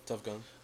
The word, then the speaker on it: Tuff Gong: Is that how it's pronounced? Tuff Gong? – Yeah, that's how it's spelled. Tuff Gong.